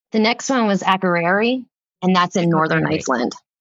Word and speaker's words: Akureyri: It's Akureyri.